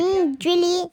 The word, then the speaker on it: Cendrillon: Cendrillon. I'm not good with French pronunciations. – Cendrillon? – That's what it looks like. – Cendrillon.